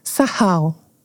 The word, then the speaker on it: sakal: Soa como sahal. Se escreve sakal, S-A-K-A-L. Mas, aparentemente, é pronunciado sahal.